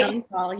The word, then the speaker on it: Chantal: Because I want to say Chantel, and it's not Chantel. It's Chantal, right? – Chantal, yes. Yeah, it's hey. Actually it's French.